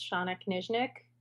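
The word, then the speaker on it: Shana Knizhnik: Both you and I have names that are constantly mispronounced, so I feel okay correcting you that it's Shayna Kaznick.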